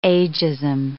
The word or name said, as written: agism